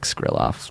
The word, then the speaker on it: Skrilov: Pronounced like Skrillex, except, like, less cool, unless you say it in a Russian accent, then 110% more cool. Thanks, Mike Skrilov. – Skrilov.